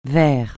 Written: verre

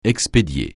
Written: expédier

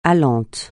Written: allant

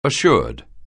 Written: assured

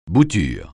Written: bouture

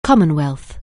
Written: Commonwealth

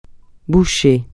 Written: bouché

